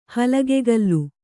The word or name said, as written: ಹಲಗೆಗಲ್ಲು